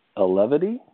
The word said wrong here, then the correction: Elevati: Is that Elevity? Is that how you pronounce it? – Elevati. – Elevati? Dadgummit. Yep. All right, Elevati.